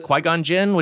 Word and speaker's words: Qui-Gon Jinn: Qui-Gon Jinn, which character, yes. – Qui-Gon Jinn.